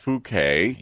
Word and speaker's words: Phuket: It's pronounced Phuket. – Yes. – I pronounced it phonetically once.